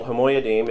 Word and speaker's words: HaMoyadim: So no, it's called HaMoyadim.